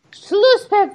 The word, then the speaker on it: Schlusspfiff: Schlusspfiff, that's not what Angie wrote. And you have to clearly wrote Schlusspfiff. – You have to make sure you make the right PF sound. It's like apple.